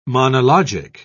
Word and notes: monologic: British English

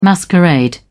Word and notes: masquerade: British English

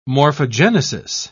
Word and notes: morphogenesis: British English

